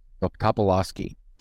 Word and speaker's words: Topolsky: That's how you pronounce it. Topolsky? Topolsky? – Topolsky. – Kind of Polish. It's definitely a Polish name.